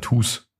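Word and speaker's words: Tooze: Das ist schon mal Tooth, auch. Sondern Toos. Ja, ja, meine äh, war schon Englisch Aussprache ist einfach schlecht. Toos. Ähm, T-O-Z.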